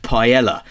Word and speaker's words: paella: "Excuse me, it's pronounced paella."